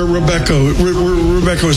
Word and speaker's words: Robeco: Depends if you're Dutch. It's Robeco.